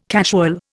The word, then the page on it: casual: Indian